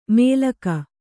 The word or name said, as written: ಮೇಲಕ